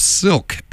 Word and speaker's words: Silque: Next up is a new one from Silk, and that is spelled S-I-L-Q-U-E, and you wouldn't believe there are actually three bands with that name.